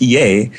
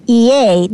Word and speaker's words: Ea: So the correct pronunciation is EA, not EA.